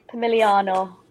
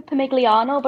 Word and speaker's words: Pomigliano: Well, when I first come here, I was like, I play for Pomigliano, but apparently you don't say the G, so I was saying it wrong for full 2 weeks. Yeah, silent G.